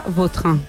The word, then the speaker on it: Vautrin: La original francesa, Vautrin.